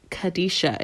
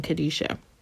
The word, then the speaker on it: qedeshah: It's number 6948 in the Hebrew section, and it's Kadesha. It's spelled Q-E-D-E-S-H-A-H. And in Hebrew, which, you know, it's, you know, isn't spelled the same, but pronouncing it, it's saying Kadesha.